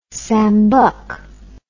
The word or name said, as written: sambuk